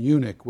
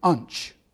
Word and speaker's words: eunuch: But I thought it was a rather comical pronunciation for the word eunuch.